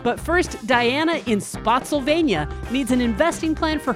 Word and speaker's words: Spotsylvania: Spotsylvania, pronounced like Pennsylvania.